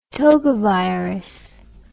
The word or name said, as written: togavirus